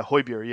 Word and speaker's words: Højbjerg: Is that how you pronounce it, Højbjerg? – Yeah, Højbjerg.